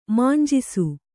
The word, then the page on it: ಮಾಂಜಿಸು: mānjisu